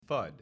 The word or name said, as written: FUD